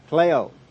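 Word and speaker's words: klaiō: If you want to spell it out, it is K-L-A-I long O. Kleo.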